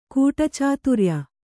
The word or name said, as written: ಕೂಟಚಾತುರ್ಯ